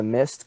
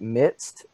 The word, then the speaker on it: mist: So today, remember when it talked about the mist coming up from the ground? You kept mispronouncing it and calling it midst, as if like you're in the midst of someone else.